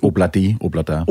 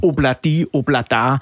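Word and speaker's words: Ob-La-Di, Ob-La-Da: noget af Oblidi Oblada. Mm. Udtaler jeg det overhovedet rigtigt? – Obladi Oblada. – Obladi Oblada. Ja.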